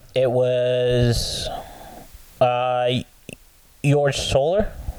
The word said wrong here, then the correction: Jorge Soler: Jorge Soler. – Yes. – I think is how you pronounce it.